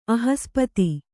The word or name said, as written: ಅಹಸ್ಪತಿ